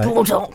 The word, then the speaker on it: Bordeaux: They don't pronounce the X. – Right. – Bordeaux, you know what I mean?